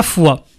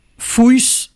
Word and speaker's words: Foix: Quant à foi, préfecture de l'Ariège, son X final ne se prononce pas en français et donne un vague fouis en occitan.